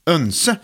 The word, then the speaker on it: oense: Vi är helt oense. Oense. Önse. O-E blir ju egentligen ö, vet du. Bjoeren till exempel. Så oense uttalas önse på svenska.